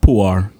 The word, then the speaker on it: Puer: Pierre. – Pierre. P-U-E-R. It's pronounced Puar. It's Latin for boy.